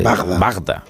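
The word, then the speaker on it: Wajda: Eh, una película maravillosa del año 83 que se llama Danton, que es de, del polaco Andrei, eh, lo voy a pronunciar mal, Bagda. – En español es Bagda. Es como Bagda.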